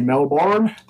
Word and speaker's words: Melbourne: I always love the way Americans say Melbourne. Yeah. We just say Melbourne.